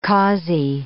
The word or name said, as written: causey